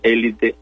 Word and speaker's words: élite: En francés más o menos se pronuncia élite, así con el acento grave. Cuando lo pasamos al español, pues obviamente nos queda ese sonido élite, ¿no? Es la recomendación élite, aunque la otra se entilde para que diga élite, no es incorrecta, pero se recomiendo mucho mejor la forma esdrújula, élite.